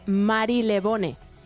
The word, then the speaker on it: Marylebone: Mary. Marylebone. Bueno, Lebon. Sí, claro, Lebon. – Bueno, es que de hecho Mary no se dice.